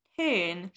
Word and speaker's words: ton: Is a French word that means manners or style and is pronounced in the French ton.